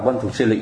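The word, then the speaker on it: Văn Thù Sư Lị: cái chữ lợi nó thì họ biết một thứ, cái chữ hữu tàu nó, chữ lợi, chữ đó mình đọc là lợi gì phải không, nhưng mà cái âm nó còn là lị nữa, mà cái người mà người ta phiên âm ra thì người ta muốn mình đọc là lị, nhưng mà khi mình đọc văn thủ sư lị nghe nó không hay bằng văn thủ sư lợi không.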